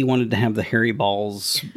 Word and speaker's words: Baals: Now, his last name fairly is spelled B-A-A-L-S, but in the Indiana funk of accent, which I'm not casting any shade upon, that's Balls. – That's Balls, I think, no matter what. – Well, somebody could be like, no, it's Bales or some weird pronunciation, but it's Balls.